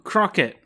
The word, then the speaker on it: Crockett: Crockett. – See, it's kind of like, it's almost like croquet, you know? – It's spelled like croquet.